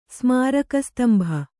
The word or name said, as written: ಸ್ಮಾರಕಸ್ತಂಭ